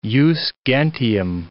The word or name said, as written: jus gentium